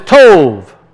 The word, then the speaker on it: tov: It's pronounced with a V. Tov. We see the B and we want to pronounce it in English. But if you see a B with a line underneath it, that's a V sound in Hebrew.